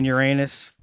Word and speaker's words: Uranus: So I mean, it's like an old Greek word, and I think that's the correct pronunciation. It's just, you know, sounds very much like something else. – Yeah, like, so people are out here planet shaming because it's saying Uranus.